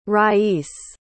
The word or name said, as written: rais